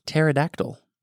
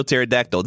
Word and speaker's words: pterodactyl: I'm going to be exotic here and go Daniel Pterodactyl. – Oh my, okay, Daniel Pterodactyl. This was a really good one. By the way. – Spelled with a P, but doesn't sound like it.